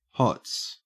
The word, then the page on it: hots: Australian English